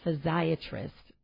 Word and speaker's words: physiatrist: And so you are a physiatrist.